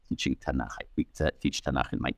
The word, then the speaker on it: Tanakh: It's the acronym of Torah, Nevi'im, Ketuvim, the law, the prophets, the writings. And when it's acronized, so we pronounce it Tanakh, Bible.